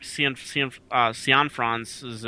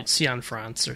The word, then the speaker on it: Cianfrance: But yeah, no, this is, I mean, Place Beyond the Pines is Derek Cianfrance's, I always screw up his name. I'm sorry, Derek. – I'm pretty sure that's right. Cianfrance or something. – Cianfrance, I believe, is how it's pronounced.